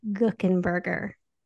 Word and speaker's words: Guckenberger: It is your Thursday bonus episode, and I'm really excited to be talking with author Beth Guckenberger.